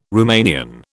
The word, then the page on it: Rumanian: British English